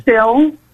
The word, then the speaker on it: sill: It's spelled S-I-L. – Okay. – Sill.